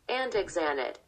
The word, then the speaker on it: andexanet: It's called andetanet. Dear guest, can you check we're saying this right? – Andexanet.